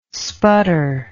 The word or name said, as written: sputter